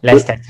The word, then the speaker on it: Leicester: So difficult, very, very difficult to pronounce, but Leicester, not Leicester.